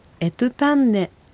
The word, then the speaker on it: エトゥタンネ: エトゥタンネの場合、第一音節はエなので、 E です。 E は母音なので、第二音節トゥの方にアクセントがついてエトゥタンネという発音になります。